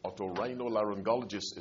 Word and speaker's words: otorhinolaryngologist: Otorhinolaryngologist is the long, tongue-twisting name.